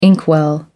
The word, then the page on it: inkwell: American English